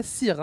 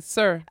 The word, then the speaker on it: Sir: Et puis, alors, puisque ça fait longtemps, Romy, ça fait longtemps qu'on n'a pas fait de point de prononciation, c'est pas Sir, hein, c'est Sir.